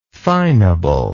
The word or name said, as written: finableness